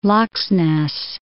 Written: Laxness

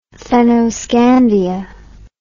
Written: fennoscandia

